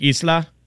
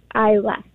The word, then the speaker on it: Isla: Yeah, that's not Isla, it's Isla. – Isla, thank you. Is that your daughter's name? – Yes, like the actress Isla Fisher. – Okay. I always thought it was Isla Fisher. I'm not kidding. Like, this is like new information for me. Thank you. – No, it's just like the, when you pronounce I, it's just without the D.